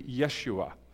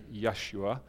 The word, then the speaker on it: Yeshua: And actually, the Jewish or the Hebrew pronunciation would actually be Yeshua.